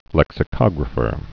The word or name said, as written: lexicographer